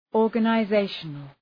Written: organizational

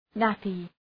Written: nappy

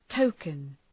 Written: token

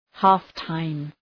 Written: half-time

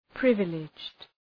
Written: privileged

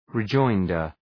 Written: rejoinder

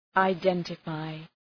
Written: identify

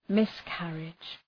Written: miscarriage

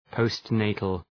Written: postnatal

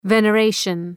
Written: veneration

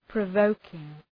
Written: provoking

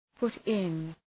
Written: put in

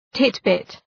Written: titbit

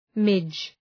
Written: midge

